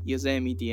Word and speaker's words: Yosemite: Comunque, lo sai che si dice Yosemite e non si dice Yosemite, che il parco è...